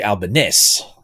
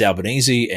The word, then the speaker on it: Albanese: It's a little bit like Anthony Albanese. I think in Italian it's supposed to be Albanese or something like that. But he says it as Albanese, and we get away with it and all that kind of stuff.